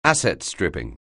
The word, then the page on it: asset-stripping: anglais